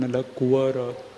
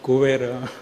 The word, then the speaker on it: Nalakuvara: Deliverance of Manigriv and Nalakuvara. Not Kuvera. A lot of guidance was given. So we should be careful in what we pronounce.